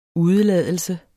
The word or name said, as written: UDELADELSE